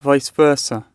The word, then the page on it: vice versa: British English